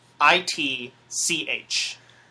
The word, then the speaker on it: Michelitch: So however you choose to pronounce it, it's spelled MICHELITCH. Just think about it as if a man named Michelle from France has an itch.